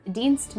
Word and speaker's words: Dienst: You should maybe spell it E-D-I-E-N-S-T. – Great. – It means Dienst means service in German.